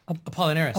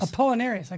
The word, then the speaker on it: Apollinaris: Apollinaris. – Apollinaris.